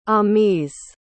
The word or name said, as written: amies